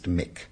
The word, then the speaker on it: Muick: Uh, that's it's spelled M U I C, but it's pronounced Mick, and that's in the Lochnagar group.